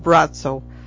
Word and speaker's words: Braco: But if anybody wanted to look him up who's in the area, it's pronounced Braco, but it's spelled B-R-A-C-O, Braco.